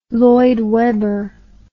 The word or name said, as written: Lloyd Webber